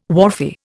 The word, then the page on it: wharfie: Indian